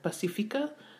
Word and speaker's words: Pacifica: If you compare the rhythm of the word in English, rhododendron, it's very similar to Pacifica.